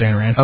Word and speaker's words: Ayn Rand: I think it's pronounced Ayn Rand. – Okay. – A-Y-N. – Well, why do you recommend that? – Why do I recommend that?